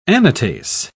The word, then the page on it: anatase: British English